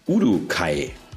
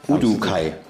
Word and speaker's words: Uduokhai: Felix Udukai. Ja, äh, ja? absolut richtig. – Udukai. – Udukai. Okay, weil geschrieben ein bisschen verrückter mit Udu und OK und H und aber so ist es richtig ausgesprochen.